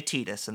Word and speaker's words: Tidus: They say Tidus, and they pronounce it just like that. – The correct way.